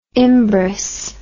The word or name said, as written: Imbros